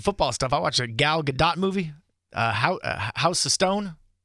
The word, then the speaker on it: Gal Gadot: It's Gal Gadot.